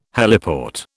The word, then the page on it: heliport: British English